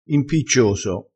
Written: impiccioso

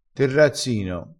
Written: terrazzino

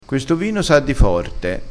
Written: forte

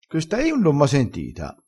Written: rivendila

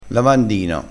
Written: lavandino

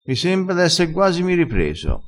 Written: esse guasimi

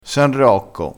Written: san rocco

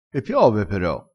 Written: pero

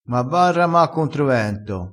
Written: rama

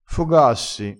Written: fogassi

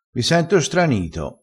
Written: stranito

